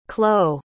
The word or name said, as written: clow